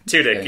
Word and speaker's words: Tudyk: I think Tudyk.